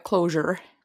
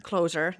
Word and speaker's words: Closer: Hetkinen, lausutaanko se Closer vai Closure? – No Onko se niinku... Closer.